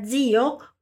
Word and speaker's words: zio: A palavra zio, por começar por Z, seguido de I, terá esse som de DZ, na verdade. Zio, zio.